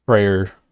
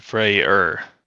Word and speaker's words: Freyr: So Freya and Freyr. – That's what I got of it. It's either Frey or Freyr. It's F-R-E-Y-R. – Huh. Okay. Yeah. And sometimes that R is silent, I guess.